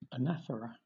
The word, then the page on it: anaphora: British English